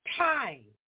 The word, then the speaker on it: thyme: Thyme. T-H-Y-M-E. I think it's pronounced thyme.